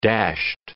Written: dashed

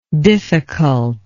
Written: difficultness

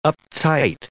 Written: uptight

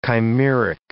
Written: chimeric

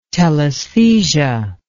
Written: telesthesia

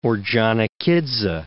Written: Ordzhanikidze

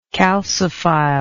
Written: calciphile

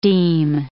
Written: deme

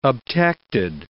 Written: obtected

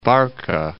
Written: Barka

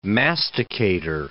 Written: masticator

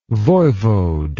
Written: voivode

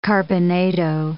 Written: carbonado

